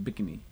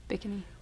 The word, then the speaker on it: Bikini: Most Americans here think of it as Bikini. – Mm-hmm. – Marshallese pronounce it Bikini. – Bikini.